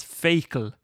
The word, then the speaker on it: Feakle: born near the village of Feakle, pronounced locally at least by the older people as Fecal.